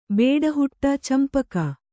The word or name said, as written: ಬೇಡಹುಟ್ಟ ಚಂಪಕ